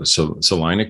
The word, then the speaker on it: Salina: I actually sometimes mispronounce it as Salina, I think, but it's Salina, Kansas.